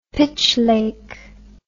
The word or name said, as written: Pitch Lake